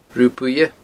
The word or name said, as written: Rubha Buidhe